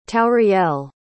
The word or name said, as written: tauriel